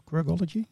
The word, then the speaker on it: Quirkology: we, we, will meet each other, but uh, it's Quirkology. Do I pronounce it yeah. well? Quirkology. – Yeah, very good.